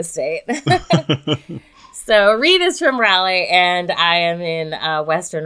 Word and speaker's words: Raleigh: And I said it right this time, Raleigh. I used to say Raleigh, and everybody's like, oh my God, please stop saying it like that.